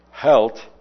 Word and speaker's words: healt: It comes from the Anglo-Saxon word halt, which is H-E-A-L-T, and it's pronounced that way, and it's translated that way because a person who is lame has to stop frequently due to their lameness.